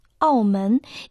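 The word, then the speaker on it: аомен (澳门): Название города Макао на китайском языке звучит как «аомен» и состоит из двух иероглифов. Первый иероглиф «ао» произносится в четвертом тоне. В переводе на русский язык это «морской борт». Второй иероглиф «мен» произносится во втором тоне.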